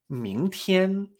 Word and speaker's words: 明天: Ming, me extraño. TIEN. primer tono. Ming TIEN.